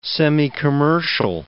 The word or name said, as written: semicommercial